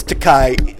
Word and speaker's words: Takei: No, it's Takei. Those closest to him know how he likes it to be pronounced.